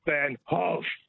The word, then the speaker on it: Van Gogh: Now, this is controversial the way you pronounce this, but it's really pronounced Van Gogh. – Van Gogh, like that. – Not Goff or Go. Somebody corrected me on it once. I thought, gee, I've got to check this out. – Vincent van Gogh. – Gogh is the way they pronounce it in the Netherlands, yes.